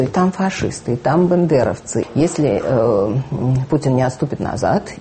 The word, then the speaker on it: бандеровцы: И вы посмотрите, что главное ругательное слово сейчас по отношению к Украине – это ведь бандеровцы. Причем так возникает, значит, это самое тогда возникшее ошибочное. У нас бывают такие устойчивые языковые ошибки, потому что бандера произносить почему-то как-то легче говорить бендеровцы, тем более, что город есть Бендеры.